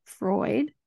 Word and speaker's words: Freud: So Tony adapted the psychological theory and teachings of Sigmund, I always mispronounce his name, Freud.